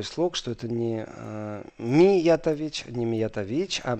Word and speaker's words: Миятович: Э, я понимаю, что это неправильно сейчас было сказано югославский, но мне иногда настолько все равно вот эта вот толерантность, так что если Миятович, спасибо, буду знать, что э, на втором слоге, не на третьем, даже не на четвертом, не на первом, но это настолько все равно с точки зрения, где стоит ударение, к самому факту.